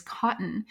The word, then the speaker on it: Kotn: And this week, the brand we wanted to shout out is Kotn.